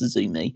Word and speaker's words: Azumi: Her name is spelled, or it's presented as AZM. Like, that is the three letters that she's associated with her name, but it's pronounced Azumi.